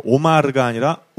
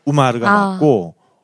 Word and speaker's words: 우마르: 하지만 원래 이제 이 아랍 쪽 발음에서는 오라고 써놓고 우라고 읽는 게 많아요. – 아. – 네. 그래서 우마르, 그러니까 오마르가 아니라 우마르가 맞고 온마이야가 아니라 운마이야가 맞고 그리고 코란이 아니고 꾸란이 맞습니다. 원래 발음은.